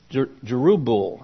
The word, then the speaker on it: Jerubbaal: His name was actually Jerubbabel, and it means discomforter of Baal. Jerubbaal, I guess would be a better way to pronounce it.